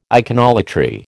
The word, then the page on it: iconolatry: American English